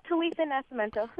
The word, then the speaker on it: Talitha Nascimento: Yeah, Talitha Nascimento. – Nascimento, okay, take the C out of it.